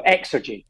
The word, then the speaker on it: Xergy: The co-founder and CEO of Energy... I've got it right. How do you pronounce it? Xergy? – No, you great. So you pronounce X strong. Xergy. – Xergy.